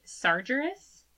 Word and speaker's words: Sargeras: S-A-R-G-E-R-A-S. – Sargeras. – No. I'm going to continue to pronounce it, because the A and the E are, so you switch the A and the E around. – Sargeras.